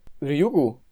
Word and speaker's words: Ryugu: Den har jo været ude ved den her, øh, den her asteroide, Ryugu. Var det ikke sådan, vi udtalte det sidste gang, tror jeg? – Øh, jo, det tror jeg, det, det er det, vi bliver enige om, vi kalder den.